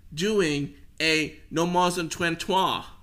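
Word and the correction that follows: ménage à trois: I completely butchered that. – Menage a trois? – Damn. Yes. Yes, that's what I meant.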